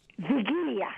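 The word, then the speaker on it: Wigilia: How do you say that again? – Vigilia. – Vigilia? – It's Vigilia.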